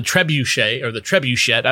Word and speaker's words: Trebuchet: But I have to give a shout out to a now defunct blog called the Trebuchet. I don't even know how to pronounce that word. – The first one was right. You got it.